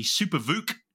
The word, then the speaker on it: Super VOOC: It's the Super VOOC charging. I'm not sure that's how you say it, to be honest.